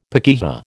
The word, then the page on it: pakeha: American English